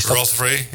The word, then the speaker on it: Rothery: Rothery, met een H ertussen. Rothery. Is dat... – Rothery, ja.